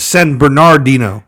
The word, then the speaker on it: San Bernardino: San Bernardino. – Yes. – Which it trended Yeah. – As a misspelling. – Yeah, it's San Bernardino. – Yep. – Not Bernardino.